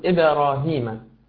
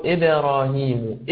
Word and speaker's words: إبراهيم: يا أخي الحميد، أنا لم أقل إبراهيم، لقد قلت وإذ يرفع إبراهيم إبراهيم إبراهيم. حاول أن تنطق هذه الكلمة بهذه الكيفية، وعليك أن تركز جيدا مع صوت القارئ الذي يقرأ وأنت تردد خلفه.